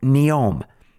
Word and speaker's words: Neom: It's called Neom.